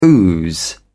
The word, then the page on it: ooze: British English